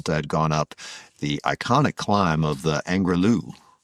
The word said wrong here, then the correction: Angliru: Angliru. I think I said that correctly.